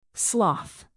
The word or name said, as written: sloth